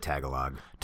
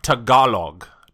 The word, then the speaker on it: Tagalog: I said Tagalog. – Tagalog, like Tagalong. – Yeah. – Without the N. – But she informed us, she told us to go into the corner because we were wrong. – Okay. – She actually texted me that. – Did she really? – Yes. – That's hilarious. – It's pronounced Tagalog.